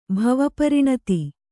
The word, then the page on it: ಭಾವಪೂರ್ಣತೆ: bhāva pūrṇate